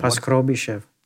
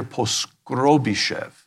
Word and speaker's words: Poskrobyshev: Poskrobyshev. – Thank you for pronouncing it. Alexander Poskrobyshev.